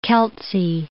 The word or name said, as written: Keltsy